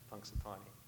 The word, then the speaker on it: Punxsutawney: I was raised in Punxsutawney, is how you pronounce that, Punxsutawney.